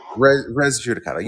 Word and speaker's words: res judicata: Res judicata.